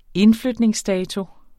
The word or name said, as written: indflytningsdato